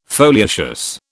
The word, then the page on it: foliaceous: British English